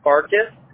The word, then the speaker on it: Farkas: Ethan, you spell it E-T-H-A-N, your first name? – Yes, ma'am. – Okay. And what's your last name? – Farkas. S is in Frank, A-R-K-A-S. – Okay. F is in Frank, A-R-K-A-S.